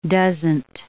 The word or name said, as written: doesn't